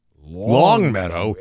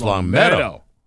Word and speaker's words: Longmeadow: You're saying it wrong. It's not East Longmeadow. – It's East Longmeadow.